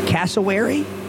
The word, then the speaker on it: cassowary: This is a cassowary? – Cassowary, yeah. Cassowary, that's it. Which starts out with the same first four letters. – How do you spell that? – C-A-S-S, Cass, like Mama Cass.